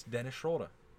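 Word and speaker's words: Schröder: Why do you say it like that? – He's German. It's Dennis Schroeder.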